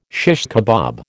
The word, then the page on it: shish kebab: American English